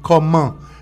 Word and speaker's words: comment: Et porque comment s'écrit C-O-M-M-E-N-T. En français, je devrais dire C-O-2-M-E-N-T. Comment. Ça se prononce comment. E-N-T est un. Et c'est le sonido un. E-N-T.